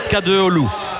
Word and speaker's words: Kadıoğlu: Oui, on va essayer Kadıoğlu, parce que c'est un I sans point et le G est muet.